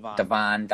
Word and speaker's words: Devin: Devon Dotson. You know, it's spelled Devin. It's the same thing.